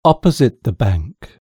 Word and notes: opposite the bank: British English

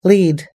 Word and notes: lead: American English

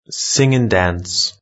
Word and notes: sing and dance: British English

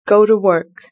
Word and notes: go to work: British English

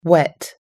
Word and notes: wet: American English

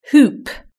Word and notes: hoop: british-english

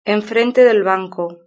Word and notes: enfrente del banco: European Spanish

